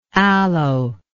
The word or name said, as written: aloetic